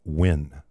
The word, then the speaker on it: Nguyen: N-G-U-Y-E-N. – Mm-hmm. – Pronounced win.